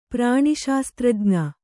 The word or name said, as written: ಪ್ರಾಣಿಶಾಸ್ತ್ರಜ್ಞ